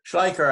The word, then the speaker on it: Schleicher: And then the second silver lining, and our mutual friend, Andreas Schleicher, I always mispronounce his last name, Schleicher. – Schleicher, I think, is Schleicher, what I hear from my German friends. – So yes, exactly. – That's the right way.